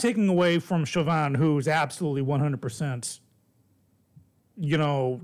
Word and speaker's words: Chauvin: Chauvin, yeah.